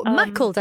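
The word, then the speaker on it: Maughold: Is it Muggold? – Oh, Mackled. I think you mean Mackled Head. Mackled, yeah.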